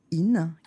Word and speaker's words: IN: vous le prononcez très bien, tout ça se dit in. il y a une petite coquetterie parce qu'il y a deux i, donc voilà, mais ça se prononce in.